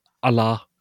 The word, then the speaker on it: Allah: Också utanför Koranen så vill man för exempel alltid ha med shaddan i Allah. Shadda är då ett märke över en konsonant som markerar att den ska uttalas två gånger. Eller då som förlänges.